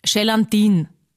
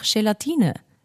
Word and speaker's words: Gelatine: Aber es gibt auch Menschen, die sagen zum Beispiel Gelatine. Und ich weiß schon, ich will jetzt auch nicht super gescheit oder obergescheit da daherreden, aber ist halt einfach falsch. Und sagt's mir, wenn ich irgendwas falsch ausspreche, aber man kann auch sagen, es ist einfach Gelatine.